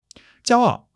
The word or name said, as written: jiao1 ao4